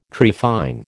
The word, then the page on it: trephine: American English